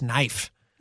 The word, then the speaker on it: Naif: One, the guy's name is pronounced, I believe the guy's name is pronounced knife. Yeah. It's like very close. – Yeah, it's spelled N-A-I-F.